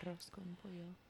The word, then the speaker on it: arroz con pollo: Did I say that right? – Yeah. – Arroz. Yeah. Because I know arroz con pollo. I know they do their R's differently than it is in Italian.